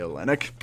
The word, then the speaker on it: Olynyk: They need more veteran leadership, and they need more scoring prowess in the paint, which is something Bam and Kelly Okunin, or how do you say that? – Kelly Olynyk. – O-L-Y-N-K-Y. Kelly Olynyk? Is that what you're talking about? – There you go.